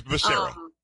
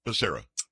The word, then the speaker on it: Becerra: It's Becerra. Becerra. – Becerra. Okay, Becerra, yes.